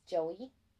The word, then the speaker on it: Joie: I believe her name is pronounced Joey Armstrong. It was J-O-I-E. – Okay. – So I believe it's Joey. – J-O-I-E? – Yeah.